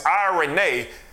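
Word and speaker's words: irene (εἰρήνη): But I looked this up in the Greek, and it's a word, irene, and that's how you pronounce it, is irene.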